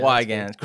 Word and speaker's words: Weigand: And sorry, did I say your last name? Weigand. – Yeah, Weigand.